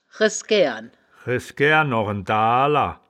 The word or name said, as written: resgaeren